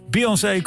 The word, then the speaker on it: Beyoncé: Noem ik een paar titels, Ziggy Marley, The Fugees en Beyoncé. Zeg ik dat goed? Ja, Beyoncé komen het eerste half uur voorbij.